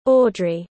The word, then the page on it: audrey: British English